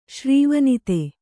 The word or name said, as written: ಶ್ರೀವನಿತೆ